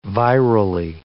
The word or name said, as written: virally